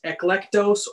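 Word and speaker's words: eklektos (ἐκλεκτός): In Greek, it is eklektos or eklektos, which sounds like the word elect almost.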